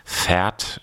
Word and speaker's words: Vaert: Without You heißt das, von Pferd. Ich glaube, ich habe das richtig ausgesprochen. Also nicht das Pferd, auf dem man reiten kann, sondern V-A-E-R-T.